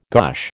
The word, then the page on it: gosh: American English